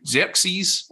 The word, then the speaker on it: Xerxes: It's Jake Xerxes Fussell. How is my pronunciation? – Yeah, I think it's good. Xerxes, I think is right. – Jake Xerxes Fussell.